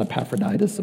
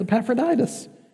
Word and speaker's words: Epaphroditus: Their dear friend Epaphroditus, uh, what's his name? Not Epaphroditus, Epaphroditus. Oh, those Greek names. Pronouncing them can be a mouthful.